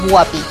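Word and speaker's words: Huapi: Es guapi, sin acento.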